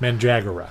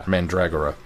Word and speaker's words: Mandragora: Andy actually sent me this is how this is pronounced. – Mandragora. – Mandragora. At least.